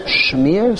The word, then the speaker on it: shmear: Smear it or smear it? How do you pronounce it? – Smear. – Not smear in Yiddish and smear in English. – Smear? Shmear? Smear? Shmear? No, smear. I don't know. – Shmear. – It's shmear.